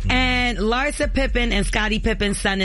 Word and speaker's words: Larsa: Larsa.